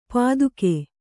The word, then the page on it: ಪತ್ತುಗೆ: pattuge